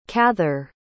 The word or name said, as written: cather